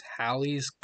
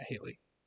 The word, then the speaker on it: Halley: And the parent body that causes the meteor shower is Halley's Comet. The famous Halley's Comet. Which, yes, it is pronounced Halley, not Haley.